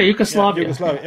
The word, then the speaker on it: Yugoslavia: I don't, Yugoslavia, how do you say it in English? – Yugoslavia. – You know what I mean? – Yeah, Yugoslavia.